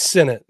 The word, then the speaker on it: Sinnott: I believe it's Sinnott. Sinnott is how I heard the announcer saying it when I was watching.